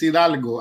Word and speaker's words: Hidalgo: It's Hidalgo. I got Hidalgo. – You. – Hidalgo. – Like Hidalgo, Texas.